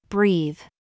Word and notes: BOB: American English

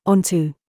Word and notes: FOOT: British English

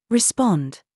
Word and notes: SAUCE: British English

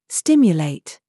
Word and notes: YOU: British English

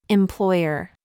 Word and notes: CHOICE: American English

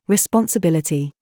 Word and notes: BOB: British English

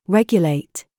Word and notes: FACE: British English

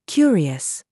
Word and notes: YOU: British English

